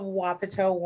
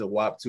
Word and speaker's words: Wapato: Thank you so much for joining us, the wonderful creator of Wapato Woman. Am I pronouncing that right? I that's how I've been saying it. Okay. – Perfect. My mom pronounced it WAP2 the other day.